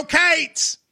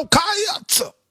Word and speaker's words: Cats: Here, so really, it's go Cats, but we in Kentucky would go, go Cates. – Go Kayats. – You're adding a syllable. It's like one syllable. Cates.